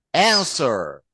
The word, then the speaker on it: answer: A N S W E R. We insist on pronouncing the W because it's there, but it is silent in English. So we say answer.